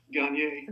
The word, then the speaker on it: Gagne: My brother and I staged a revolution and said, we're not saying Gagne anymore. We're going back to the more elegant Gagne.